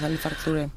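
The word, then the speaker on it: Ali Farka Touré: Πρώτο, από τον παραδοσιακό καλλιτέχνη τους εκεί πέρα, τον Άλι Φάρκα Τουρέ. Δεν ξέρω αν το προφέρω και σωστά κιόλας. – Καλά το λες, Άλι Φάρκα Τουρέ.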